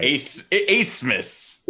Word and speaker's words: Abmas: Abmas. I hope I'm saying that right. – Acemus. Yes, really. Acemus. – So I'm not saying it right. – That B is pronounced like a C-E.